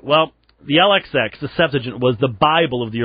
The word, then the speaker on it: Septuagint: Now, some people pronounce that Septuagint. I pronounce it Septuagint.